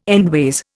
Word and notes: endways: Indian

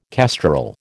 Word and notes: kestrel: American English

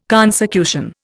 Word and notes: consecution: Indian

